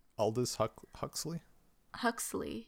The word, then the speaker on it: Aldous Huxley: Aldous Huxley.